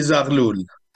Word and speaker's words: Zarhloul: Mehdi Zarhloul, did I say that correctly? I know I abused your last name. – It's say, okay, David. Mehdi Zarhloul. – Yeah, Zarhloul. Okay, I knew I'd badger it.